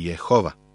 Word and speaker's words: Jehova: Gospod je na jevrejskom tetragram, koji je transkribovan kao Jehvh, a na našem jeziku se izgovara kao Jahve ili Jehova.